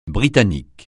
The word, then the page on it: Britannique: francais